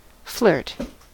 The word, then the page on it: flirt: American English